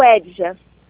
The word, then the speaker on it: Edja: Como pronuncia seu nome mesmo? – O Edja. – O Edja. – O Edja. – Isso.